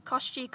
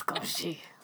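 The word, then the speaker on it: Koschei: I've found ways to pronounce it, so Gaucher. We'll both probably say 10 different... Koshi. There's several, and they're all pretty cool and also Russian. So... – Gaucher. It's Gaucher.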